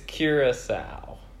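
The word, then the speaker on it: Curaçao: It's Curacao. – What? – Curing a... – Sow. – A female pig. – Is that the word? – Curacao? – I don't know.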